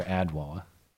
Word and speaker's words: Adwoa: A-D-W-O-A. – Love you guys. – No. Not did he write Edwoa or Adwoa? – Edwoa, but woe was spelled like whoa. – Oh, it's Adwoa, and it's not like whoa. It's just a W-O.